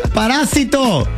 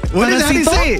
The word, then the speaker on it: parásito: Parásito. – Parásito. Parasato. Parasato. – Parásito. – Parásito. – Parásito. Ese mero.